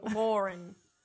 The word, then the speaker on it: Lauren: I feel like I say the name Lauren wrong because my name is Lauren. Do I pronounce it weird? – I don't think so.